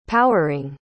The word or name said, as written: powering